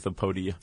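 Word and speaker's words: Apoidea: How do you say that? – Let's go with Apodia.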